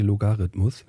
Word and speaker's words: Logarithmus: Ich habe jetzt gerade ähm, beim Korrigieren habe ich Logorhythmus gesagt. Das heißt natürlich Logarithmus.